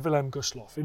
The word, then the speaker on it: Wilhelm Gustloff: Here's Roger Morehouse and the sinking of the Wilhelm Gustloff. Enjoy. Roger, did I pronounce it correctly? – Just about, Dan. Yeah, that was fine.